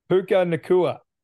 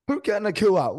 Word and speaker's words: Puka Nacua: Is it Puka or Puka? – Puka Nacua. – Puka Nacua.